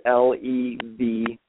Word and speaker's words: Leveen: The last name is Leveen.